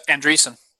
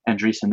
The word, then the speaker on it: Andreessen: Mark Andreessen behind it. – Andreessen. – Yeah. Andreessen, sorry. I always mispronounce his name. Andreessen, thank you, Zach.